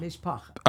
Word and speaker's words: Mishpacha: And I'll end with this one, Mishpacha. Is that how am I saying that right? Me- Mishpacha. – Mishpacha.